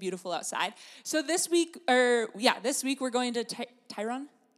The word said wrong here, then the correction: Tryon: Is that right? Tyrone Creek. Is that how you pronounce it? I'm not from here. Tryon, thank you, Tryon Creek.